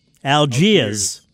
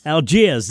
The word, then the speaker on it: Algiers: Number three, Algiers. You're not saying it right. Algiers. Can you say it right or not? – Algiers. – There you go. That's right.